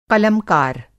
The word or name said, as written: क़लमकार